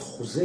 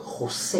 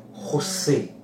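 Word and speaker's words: José: אז ההגייה המדויקת, כמו שאמרת והתלבטת לגבי השם, פחות חוזה, יותר חוסה. אה... קסטג'אנו, בספרדית, כי הקובאנים מדברים מן ספרדית מתנגנת שכזאת, חוסה.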